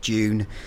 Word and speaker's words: DVNE: DVNE, who I think are pronounced Dune.